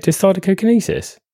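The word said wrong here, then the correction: dysdiadochokinesis: No, it's not. It's dystidiochokinesis.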